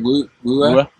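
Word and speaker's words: Wuher: Is it Wooer? – Yeah.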